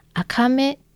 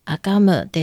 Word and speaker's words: AKAME: このレストランの名前はローマ字で、 AKAME と書くから、アカメと読みたくなりますが、正しい読み方はアカムです。这个店名不是中文、是原住民语。今、台湾で認められている原住民族は全部で 16 あります。アカム